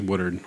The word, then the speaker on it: Woodard: Woodward. – Woodward. Yeah. – Woodward? Woodard. – Woodard? – I think it's Woodard.